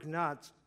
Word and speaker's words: gnats: Aaron stretched out his hand with his staff and struck the dust of the earth, and there were gnats. How do you say that? Gnats? Okay, just get rid of the G.